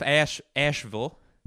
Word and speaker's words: Asheville: Now, the thing with Asheville. That's how the locals pronounce it. You fucking carpet-bagging northerner. You say Asheville. Here in... We call it Asheville.